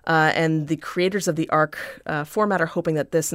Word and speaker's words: Arch: That's called the ARC 1.2. It's spelled like arch, but it's pronounced like arc.